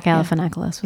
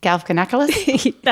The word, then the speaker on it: Galifianakis: Yeah, Zach Galifianakis was there. – Galifianakis? – I added a syllable.